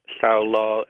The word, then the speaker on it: Sao la: The Vukrang Oxys Sao La, as it's pronounced in Vietnamese, discovered in 1992.